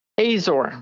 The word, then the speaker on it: Azor: And when I saw your last name was, I believe it's pronounced Azor. Is that right? – Azor. – Azor. So my mother, her maiden name is Azar, A-Z-A-R.